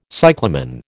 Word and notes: cyclamen: American English

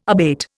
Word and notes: abate: Indian

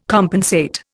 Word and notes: compensate: Indian

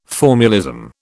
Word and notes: formulism: British English

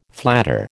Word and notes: flatter: American English

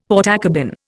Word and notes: Portakabin: Indian

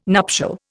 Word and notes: nuptial: Indian

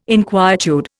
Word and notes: inquietude: Indian

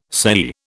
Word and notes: sei: British English

